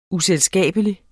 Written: uselskabelig

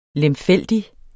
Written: lemfældig

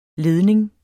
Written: LEDNINGERS

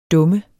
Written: dum

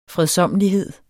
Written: fredsommelighed

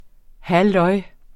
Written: halløj

